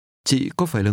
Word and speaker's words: Chị: Chị. Chị. – This word for you is pronounced with a low, falling, broken tone.